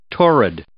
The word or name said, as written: torrid